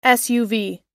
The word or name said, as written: suv